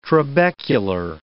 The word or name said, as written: trabecular